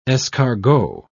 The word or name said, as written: escargot